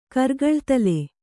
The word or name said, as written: ಕರ್ಗಳ್ತಲೆ